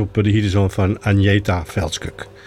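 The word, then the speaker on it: Fältskog: Hier de zoon van Agneta Veldskuk, want zo schijn je dat te moeten uitspreken.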